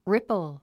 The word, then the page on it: ripple: rípl; リプル